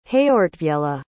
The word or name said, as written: heortwiella